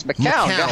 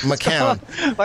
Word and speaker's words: McCown: Can we get his name right? It's McCown. – McCown.